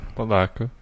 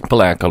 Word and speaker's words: Palatka: Uh, North Placa. Placa. Palatka, yeah. Palatka, North Palatka, Florida.